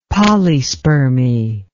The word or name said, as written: polyspermy